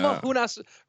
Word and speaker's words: Namajunas: Well, first of all, tell me that you really think that that's how you pronounce the last name of Rose. Just really go through that one again. – Namahunas? Namajunas? – That was better.